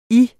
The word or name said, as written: Jer